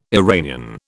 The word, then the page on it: Iranian: British English